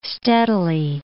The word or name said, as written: steadily